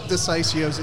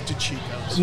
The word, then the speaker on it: DeCicco's: Pronounce it how you want, but it is DeCicco's definitely.